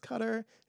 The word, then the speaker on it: Qatar: So we're in, like, Dubai, and I know it's pronounced Qatar. It just feels like Qatar. – I should say Qatar. I know, but they say Qatar.